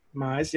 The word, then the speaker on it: Mize: Plus, the Miz, Mize, what's the official pronunciation? – I believe it's Mize. – Mize, yep.